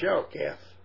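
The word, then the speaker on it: Shalketh (שָׁחַת): And the word there for marred is 7843. And it is pronounced Shalketh.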